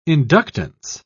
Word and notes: inductance: British English

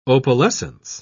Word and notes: opalescence: British English

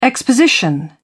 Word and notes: exposition: British English

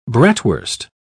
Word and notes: bratwurst: British English

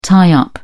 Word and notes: tie up: British English

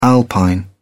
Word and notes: alpine: British English

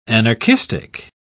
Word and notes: anarchistic: British English

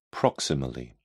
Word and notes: proximally: British English

